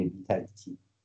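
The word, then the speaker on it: بیتکچی: اون بی تکچی هم به معنای محاسب و حساب رست، تلفظش هم همینه بی تکچی، چون آبانویس انگلیسیش شو نوشته کنارش به دلوی.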